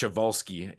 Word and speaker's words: Przewalski: But it's pronounced Przewalski, even though the spelling is a little bit interesting.